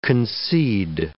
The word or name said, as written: concede